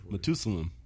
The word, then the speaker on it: Matusalem: How you pronounce it? Not to, it rhymes with Jerusalem. – Hey, just put that bitch on Google. – Matusalem. – Maeda Salem.